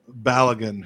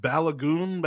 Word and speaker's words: Balogun: But let's drill down on Balogun, who, and now I made fun of this, but apparently he does prefer Balogun, because the guy on the TV tonight was just Balogun, Balogun, Balogun. And I've never heard it pronounced like that, but apparently that is the Nigerian pronunciation for it.